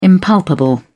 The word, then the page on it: impalpable: British English